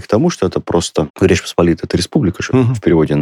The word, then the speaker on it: Речь Посполитая: то ударение ставится по-другому. Речь Посполитая.